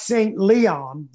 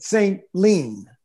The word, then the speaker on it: St. Leon: It looks like St. Leon, but if you're from there, they pronounce it St. Lean, St. Lean.